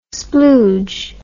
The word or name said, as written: splooge